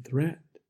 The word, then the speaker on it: threat: Watch out for meat, and grate, and threat. They rhyme with sweet, and straight, and debt.